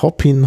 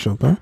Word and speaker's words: Chopin: Mhm Mm, das ist nicht falsch ausgesprochen. Also man könnte nicht denken, dass er vielleicht in Wirklichkeit Hoppin heißt. – Mhm. Man heißt wirklich Chopin. Denn sein Vater war, äh, war Franzose.